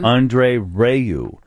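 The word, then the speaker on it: André Rieu: Andre Rayu with his 60-piece Johann Strauss Orchestra. – He's the waltz king. – Is he? – Yeah, he's huge. – Johann Strauss or Andre Rayu? – Andre Rayu.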